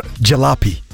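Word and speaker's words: jalopy: Джалапи. – Ой, это даже переводится. – Да, джалапи, да. – Джалапи? – Да. То есть пишется джалопи, а произносится джалапи.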